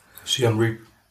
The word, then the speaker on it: Siem Reap: Siem Reap.